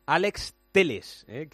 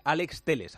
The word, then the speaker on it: Telles: Bueno, en el Sevilla, uno de los nombres del día está al caer Alex Teles, que se dice así, me ha dicho Fernando Evangelio, que hay que decirlo bien, que el brasileño es Alex Teles, aunque se escribe Telles, a ver si nos acostumbramos.